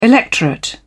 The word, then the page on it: electorate: British English